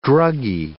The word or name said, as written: druggy